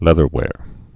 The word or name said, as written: leatherwears